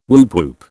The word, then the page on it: woop woop: British English